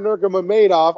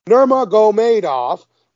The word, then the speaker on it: Nurmagomedov: You got Umar Nurmagomedov. Oh, I just said that wrong. Nurmagomedov versus Nate Maness.